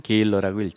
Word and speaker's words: gala: 우리가 여기선 게일러라고 읽지만 우리나라 말로 할 때는 그냥 갈라라고 하면 되겠습니다.